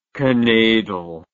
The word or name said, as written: kneidel